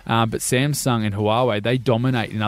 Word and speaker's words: Huawei: And Huawei? Is that how you say it? – I don't know. You're the expert. – Huawei. I'll say it. It's Huawei, the Chinese brand.